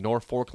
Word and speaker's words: Norfolk: Here is a text coming in regarding when I said that Sip is staying in Norfolk last night.